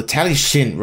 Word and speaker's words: Talyllyn: In fact, there's a railway in Wales called the Talyllyn Railway. – Okay. The what, sorry? – The Talyllyn Railway. I'm just trying to pronounce it the Welsh way.